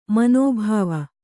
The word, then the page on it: ಮನೋಭಾವ: manōbhāva